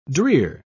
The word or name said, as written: drear